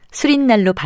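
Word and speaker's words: 수릿날: 단어와 수리는 모두 모음으로 끝나고 뒷말인 날의 첫소리 니은 앞에서 니은 소리가 덧나서 각각 단원날, 수린날로 발음되기 때문에 사이시옷이 붙습니다.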